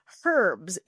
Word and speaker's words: herbs: But here's the thing that I wanted to point out for everybody listening is that you pronounce the H, right? Herbs. You say it in the British manner, whereas most of us Americans would say herbs.